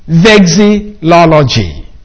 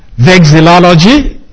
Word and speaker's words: vexillology: I mean that very few have ever been involved in vexillology. Spell it so that you can pronounce it right. Anyway, vexillology, V-E-X-I-L-L-O-L-O-G-Y.